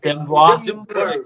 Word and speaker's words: Dymbur: Dymbur, actually.